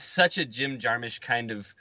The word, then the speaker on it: Jim Jarmusch: It's Jim Jarmusch for Stranger Than Paradise.